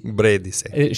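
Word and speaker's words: Brédice: Leticia Bredici? Bredice? – Bredici? – Bredice.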